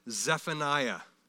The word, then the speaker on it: Zephaniah: Now, the next one, number eight in our list of 12, we would pronounce it Zephaniah, okay? And from here on, probably most of us will continue to pronounce it that way. It's Zephaniah.